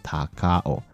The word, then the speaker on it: 高雄: 高雄两个字在日文里面念起来就叫做“ 塔卡欧”。